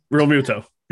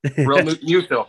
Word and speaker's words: Realmuto: Realmuto. – Realmuto.